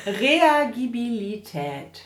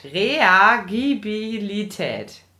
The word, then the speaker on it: Reagibilität: Mit der Betonung ist sie damit so... – Reagibilität. – Reagibilität. – Ja. – Also wie reagieren erstmal? – Ja, genau. – Aber das ist die Möglichkeit zu reagieren. Reagibilität.